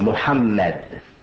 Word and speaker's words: Muhammad: But the name of our Prophet is the same, and is to be pronounced the same, regardless to how the spelling varies. It's Muhammad.